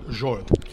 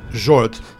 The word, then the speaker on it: Zsolt: If you want to correctly pronounce it, it's called Zsolt. – So. – Typical Hungarian name, but normally it's quite difficult to pronounce in other languages. So Zsolt is the correct pronunciation.